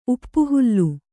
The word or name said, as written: ಉಪ್ಪುಹುಲ್ಲು